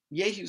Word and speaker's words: Jehu: Now, his name starts with a J in English, but it's actually pronounced Jehu.